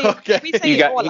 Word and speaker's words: Åland: How do you pronounce it again? The Åland's Island? – Yeah, that's also right. We say you got Åland. You got really lucky.